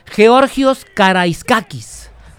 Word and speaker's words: Georgios Karaiskakis: Georgios Karaiskakis. Tiene un acento en esa A intermedia, que es diferente para nosotros.